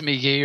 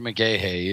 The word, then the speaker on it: McGahee: Yeah, but I mean, I don't know if it's McGee or McGahee either. I always want to say McGahee as the way it's spelled.